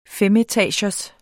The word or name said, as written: femetagers